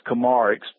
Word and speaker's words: Komarek: My last name is Komarek, spelled K-O-M-A-R-E-K, Ed Komarek.